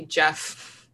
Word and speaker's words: Geoff: Menyalov, who was a grizzled Russian volcanologist and a veteran of the field, and Geoff Brown, who was a British, I guess that would just be Jeff. – Jeff, it's spelled Geoff. – Yeah. – So yeah. My brain was like, Geoff. Yeah, it's just Jeff.